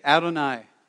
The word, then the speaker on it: Adonai: And that's how you say it, Adonai.